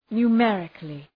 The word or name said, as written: numerically